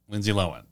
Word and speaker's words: Lindsay Lohan: You've been saying it wrong. Update from the pod. – Lindsay Lohan. – That's how she pronounces her own name.